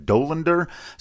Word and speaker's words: Dolander: I'd been pronouncing it Dolander. I've heard more people now saying Dolander, so I think that might be correct.